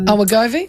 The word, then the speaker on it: Wegovy: Wegovy? – The diet.